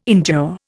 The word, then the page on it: endure: Indian